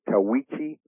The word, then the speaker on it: Tahuichi: It's spelled T-A-H-U-I-C-H-I. Tawichi Soccer Academy.